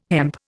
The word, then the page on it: hemp: Indian